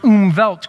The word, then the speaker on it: Umwelt: It's called its Umwelt, U-M-W-E-L-T, but it's a German word, so it's pronounced Umwelt. Go ahead and say Umwelt.